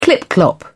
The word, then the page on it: clip-clop: British English